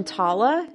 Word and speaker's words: Tala: Living a couple hundred yards from Tala. Is that how you say it? T-A-L-A? – Yeah.